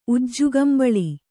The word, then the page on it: ಉಜ್ಜುಕಂಬಳಿ: kannada